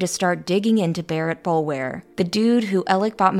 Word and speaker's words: Boulware: And just to clear the air, Bowler is spelt like bowl ware, but from what the locals tell me, it is apparently pronounced Bowler, at least with the older generations.